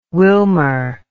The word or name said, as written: Wilmer